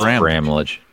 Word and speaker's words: Bramlage: Isn't it Bramlage? – It's Bromwich, isn't it? – Bromwich? – I don't know. – It's Bramlage.